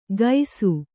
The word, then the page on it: ಗೈಸು: Kannada